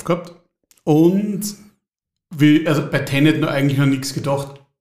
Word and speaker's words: Tenet: Wir reden über Tenant, habe ich es richtig gesagt? – Na. – Wie heißt es richtig? – Tenet. Tenet.